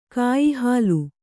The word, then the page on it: ಕಾಯಿಹಾಲು: kāyihālu